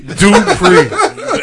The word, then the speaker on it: Dupri: Hey, Jermaine Dupri? Dupri. That's how you say it.